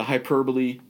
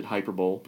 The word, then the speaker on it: hyperbole: Uh, I mean, you know, we can we can you know we can play the the, the, the hyperbole card all we want. Uh, I used to pronounce it hyperbole because I like to pronounce things the way they're spelled.